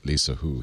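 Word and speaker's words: Lissajous: By the way, this is pronounced. You were right, Lisa Hu. He's French, Jules Antoine Lisa Hu.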